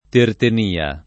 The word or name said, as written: Tertenia